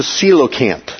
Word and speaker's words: coelacanth: Here's the coelacanth. That's how that's pronounced.